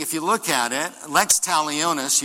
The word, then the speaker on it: lex talionis: In Latin, it is lex talionis.